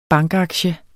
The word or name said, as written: bankaktie